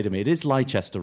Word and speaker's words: Leicester: Most importantly, he knows how to pronounce Leicester. We welcome back to the pod, Arlo White. – Hello, chaps. It's Leicester.